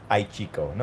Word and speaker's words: Aichiko: And that would be Aichiko. I'm probably not pronouncing it correctly. – Aichiko. No, you got it. Like, so, ai.